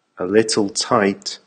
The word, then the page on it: a little tight: American English